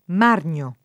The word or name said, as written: Margno